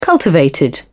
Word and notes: cultivated: British English

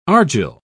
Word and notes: argil: British English

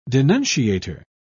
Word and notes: denunciator: British English

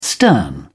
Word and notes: stern: British English

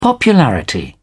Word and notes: popularity: British English

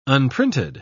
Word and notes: unprinted: British English

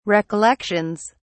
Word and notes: recollections: British English

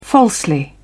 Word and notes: falsely: British English

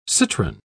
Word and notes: citron: British English

